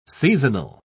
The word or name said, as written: seasonal